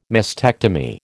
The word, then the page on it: mastectomy: American English